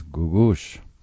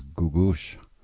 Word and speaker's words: Googoosh: And her name is Gugush. Her stage name is Gugush. And it's spelled like it sounds, G-O-O-G-O-O-S-H.